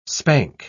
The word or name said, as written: spank